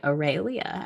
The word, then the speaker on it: Aurelia: So the way that I say my name is the way that I was given it. I say Aurelia, as you hear in the intro. And I've noticed I have a lot of shame around this because I am not saying it correctly, quote unquote, correctly. And ever since I moved to Texas, I've been in Texas for like 10, how long have I been here, Brittany?